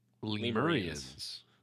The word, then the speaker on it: Lemurians: It's spelled like lemur and then Ian's. – Lemurians.